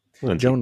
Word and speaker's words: Lindsay: Come on. Lindsay. Joan course Lindsay. It's Lindsay. What are you talking about? Who would ever mispronounce something like that? – That she might have, I don't know her.